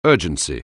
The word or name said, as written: urgency